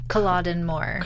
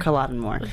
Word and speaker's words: Culloden: But so they've reached like 3 days before the Battle of, I always want to say it wrong, Culloden Moor. – Culloden Moor.